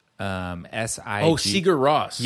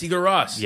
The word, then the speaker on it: Sigur Rós: And then the other band would have been, I never know how to pronounce them, but Sugar Rios or Sugar Rios. – Sugar Rios. – They're Nordic. – Sigur Rós. – Yes. – Sigur Rós.